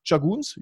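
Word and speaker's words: Tschagguns: nach Chaguns. Julia, spricht man das so aus? – Ja, Richtig. Ja, sehr gut.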